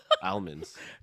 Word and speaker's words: almonds: You obviously pronounce the L. – Almonds. – No, it's almonds. – Well, I hear the L. – Oh, yeah, okay. Like an ALM sound is like an om, like om. Psalm.